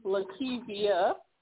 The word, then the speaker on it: Lakeba: It's actually Lakeba.